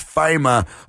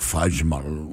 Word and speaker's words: Faima: Violet looked up the interview where he was explaining how to pronounce it. It's Faima, but that's the English way to do it. If you want to get really Dutch, it's like Fajma, right? – Whatever.